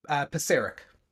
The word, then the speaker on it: Pisarek: Did I say it right? Pizarik? – Very close. Pizarik.